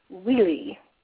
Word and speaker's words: Wili: So I'm happy to answer any questions about Wheelie, and we actually use the sort of Spanish pronunciation, Wheelie, W-I-L-I.